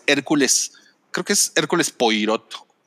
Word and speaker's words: Poirot: Ah, mira, sí es Poirot. Poirot, ajá.